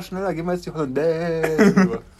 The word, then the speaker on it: Hollandaise: oder so und dass die Betonung in der Küche eine andere, wie sie eigentlich ist, weil die Betonung ist eigentlich auf das Es am Ende. Ach so, aber wir sagen Hollandaise.